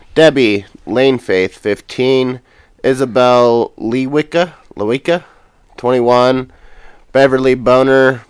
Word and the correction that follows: Bonner: 49. Bonner. – Man. – I'm sorry. Sorry, Beverly. Bonner, 49.